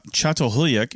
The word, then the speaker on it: Çatalhöyük: And again, we're talking about Çatalhöyük in Turkey, and that is how you pronounce it.